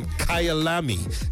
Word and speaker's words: Kyalami: So there's Kyalami, yeah, that's how it's pronounced, in South Africa.